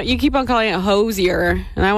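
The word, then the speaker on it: Hozier: I pronounce it kind of like with a very soft SH sound. Hosier.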